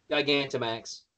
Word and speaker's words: Gigantamax: Gigantamax.